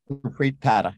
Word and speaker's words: Gurpreet Padda: Did I say that right, Padda? – Gurpreet Padda, yeah.